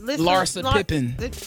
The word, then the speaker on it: Larsa Pippen: So Future put Lissa Pippen. How you say it? – Larsa Pippen.